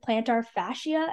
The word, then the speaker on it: plantar fascia: Joel Embiid might be dealing with plantar fascia.